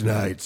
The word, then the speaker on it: Knight: It's Knight, spelled with a K. – Ah, yeah, of course. – The K is silent, like me.